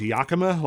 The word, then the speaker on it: Yakima: I think it's pronounced Yakima, like the city of Washington.